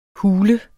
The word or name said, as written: hule